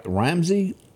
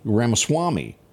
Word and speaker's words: Ramaswamy: Said that Ramsey? How do you say his name? – Ramaswamy.